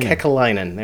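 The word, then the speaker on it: Kekäläinen: Kekalainen, there we go.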